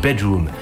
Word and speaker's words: bdrmm: BDRMM, BDRMM, prononcé Bedroom, soit chambre en français.